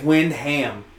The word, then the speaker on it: Wyndham: I think it's Windham.